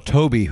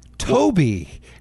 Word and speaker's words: Tobe: I might call it a Tobe Hooper movie. – Isn't he Tobe? – No. Toby.